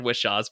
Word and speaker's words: Whishaw: And I'm not 100% sure on Ben Whishaw's pronunciation there.